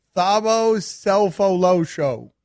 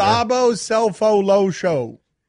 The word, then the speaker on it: Thabo Sefolosha: Thabo Sefolosha. – I mean, it's getting better as you pronounce it better. – Thabo Sefolosha.